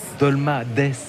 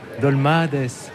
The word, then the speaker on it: Dolmades: Si on prononce bien Dolmades. Dolmades. Dolma, il faut faire traîner le A. Dolmades.